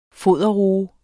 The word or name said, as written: foderroe